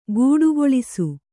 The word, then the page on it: ಗೂಡಗೊಳಿಸು: gūḍagoḷisu